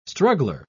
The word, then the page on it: struggler: British English